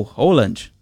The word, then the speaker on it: Haaland: Vem aí a Noruega de Haaland, ou Holland, né? Como diz a pronúncia em norueguês.